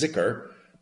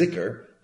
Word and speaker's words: zekr: But it's spelled in the English transliteration, D-H-I-K-R. You might run across another version, Z-E-K-R. That's zikr. And Abdullah told me the difference comes from the fact that the classic Arabic pronunciation is the dhikr, and the Egyptian dialect pronunciation is zikr, but they mean the same thing.